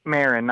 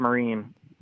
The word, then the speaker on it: Marin: It's pronounced Cheech Marin, not Cheech Marin.